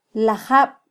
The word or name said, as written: lajab